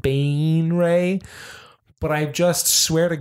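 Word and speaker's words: Baenre: Jarlaxle Bane Ray. – That's right. It has a pronunciation guide. And I found out that it's not Bon Ray.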